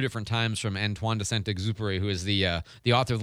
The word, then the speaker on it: Antoine de Saint-Exupéry: One of my favorite aviation authors, Antoine de Saint-Exupéry.